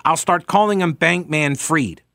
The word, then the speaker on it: Fried: but the second vowel is pronounced in the name with Germanic derivation, F-R-I-E-D, is pronounced Freed." Yes, I am aware that everyone is pronouncing it Freed, but I don't like pronouncing it that way.